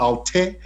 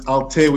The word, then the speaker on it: alté: But it's, the genre is actually called alté, because alternative. It's alté with an accent on the E.